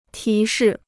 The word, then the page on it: 提示: tí shì